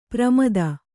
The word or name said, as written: ಪ್ರಮದೆ